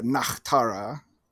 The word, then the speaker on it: Nachtara: And then the German name is Nachtara. – Love that pronunciation. – Yeah, Nachtara.